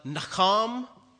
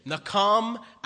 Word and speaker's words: nacham: And the two words are nakam, you like that, nakam and shaub. Nakam is spelled N-A-C-H-A-M. And shaub is spelled S-H-U-W-B.